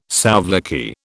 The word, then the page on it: souvlaki: British English